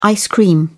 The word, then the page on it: ice-cream: British English